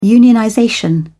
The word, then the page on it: unionization: British English